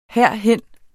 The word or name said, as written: herhen